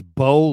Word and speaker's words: Baux: His name's Bo Limmer. Mm-hmm. Bo is spelled B-A-U-X, Limmer.